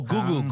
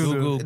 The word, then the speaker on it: Gugu: That's the girl from Juju Mbatha Raw. – Oh, Gugu. – Gugu Mbatha Raw. – You know anybody's name? – I don't know how it's pronounced. – It's Gugu.